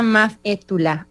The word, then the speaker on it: Maf É Tulà: Y bueno, más temprano les medio revelamos quién tenemos en el podcast hoy y tenemos a Maf É Tulà.